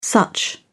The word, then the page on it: such: British English